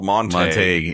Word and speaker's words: Montague: There's a county northwest of here that's – It's Shakespeare, right out of Shakespeare, Montague, but it's called Montague.